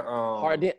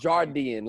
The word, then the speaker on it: Hardeen: I thought it was Jardeen. Like, everybody says Jardeen, but it's the A. – So, Hardeen. I always thought it was Jardeen. It's Hardeen.